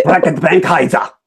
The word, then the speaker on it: Reckitt Benckiser: The Reckitt Benckiser has now split into two companies. And honey, you said it wrong. – I can't say it like you do. – Reckitt Benckiser. – There you go.